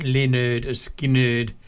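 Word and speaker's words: Lynyrd Skynyrd: Pronounced Leonard Skinner.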